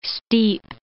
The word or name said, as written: steep